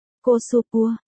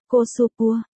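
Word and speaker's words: cosupua (コスプレ): được phát âm là cosupua, cosupua.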